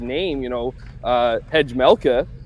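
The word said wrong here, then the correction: Vejmelka: or whatever you want to say. Vemelka.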